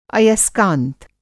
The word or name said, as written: अयस्कांत